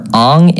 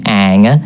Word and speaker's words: Aang: You went for Aang, it's Aang. It's a double Aang.